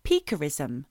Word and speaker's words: piquerism: He had a signature behaviour of something called peekerism, which is spelt P-I-Q-U-E-R-I-S-M.